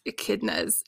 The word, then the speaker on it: echidna: And I didn't know how to pronounce echidnas at that time. So I was like, echidnas, echidnas. – Something with a weird name. – Yes. So it's an echidna.